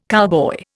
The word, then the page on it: cowboy: Indian